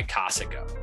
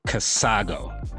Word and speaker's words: Casago: Hey everyone, this episode is brought to you by Casago. And we— you mean Casago? – No, I meant Casago. – That's not how you say it.